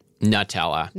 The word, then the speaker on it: Nutella: And Ferrero, which makes those, also makes Nutella, which I've heard some people pronounce Nutella. How do you pronounce it? – Nutella.